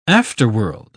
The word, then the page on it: afterworld: British English